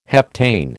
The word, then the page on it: heptane: American English